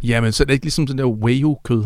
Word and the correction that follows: Wagyu: hedder det ikke det, hvor der er rigtig meget Wagyu. – Fedt i? Wagyu. – Wagyu. – Hedder det? – Ja. – Wagyu-kød. Nå, jeg har udtalt forkert.